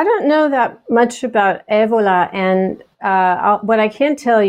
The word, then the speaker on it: Evola: Julius Evola. Is that right? Did I say it right? – Yeah, Evola. – Evola. That's so much better.